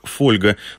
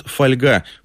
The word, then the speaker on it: фольга: К примеру, привычная нам «фольга» до середины 20 века произносилась как «фольга». Но как только этот предмет, а вместе с ним и слово, получили широкое распространение, в словарях закрепили вариант «фольга».